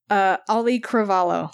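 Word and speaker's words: Auli'i Cravalho: Ali Cravallo.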